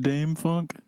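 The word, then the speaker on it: Dâm-Funk: Dom Funk? – Yes, Dom Funk is on there. – From Stone's Throw? – Yep. – Yeah, because like, I... – It's actually pronounced Dame Funk.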